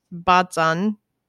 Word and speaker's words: Baztan: I'm making it sound like I'm saying Boston. Baztan. – Well, it's not Boston.